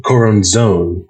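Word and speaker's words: Choronzon: And the most on-the-nose one that I can think of is in Crowley, there's this Coronzon? I don't know how to pronounce it. – Coronzone. Pardon me with the accent on the final syllable.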